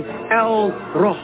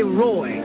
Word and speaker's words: El Roi: You'll find out that she called on El-Roh. El-Roh. Read it. They say Roy. E-L-R-O-I. That's a mispronunciation from the Greek and the Latin of Ra.